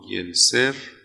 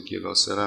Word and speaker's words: گلسر: فردیست به نام گلسر، تلفظ گلاسر هم میشه، ویلیام گلسر، مبدع یه مکتب روان درمانگری است به نام واقعیت درمانی.